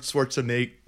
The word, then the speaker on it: Schwarzenegger: Arnold Schwarzenegger.